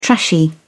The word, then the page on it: trashy: British English